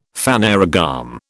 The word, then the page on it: phanerogam: British English